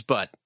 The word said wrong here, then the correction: Butte: B-U-T-T-E? You know, that's a geographical term, you know? – Yeah, but this is a set for kids. – That's what it's a, you know, I think it's pronounced Butte.